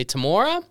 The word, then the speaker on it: Temora: Not for a little bit, but this time, and I hope I'm pronouncing it correctly, Temora? – Yeah, it's one.